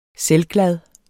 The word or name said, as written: selvglad